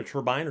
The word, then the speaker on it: turbine: and look up the word T-U-R-B-I-N-E, it'll say there's an alternate pronunciation. You can pronounce it either turbine or turbine. And generally speaking, the American pronunciation is turbine. The British pronunciation is turbine. However, it's an alternate pronunciation. You can do either one.